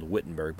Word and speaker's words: Wittenberg: That's spelled Wittenberg, but it's pronounced with a W is a V in German, right?